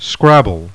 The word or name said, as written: SCRABBLE